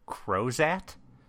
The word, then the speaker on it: Crozat: I think we need to stop and have you explain what a Crozat is. – Okay. – Am I saying it right? – Yes. Yes, you are.